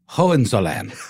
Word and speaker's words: Hohenzollern: Hohenzollern.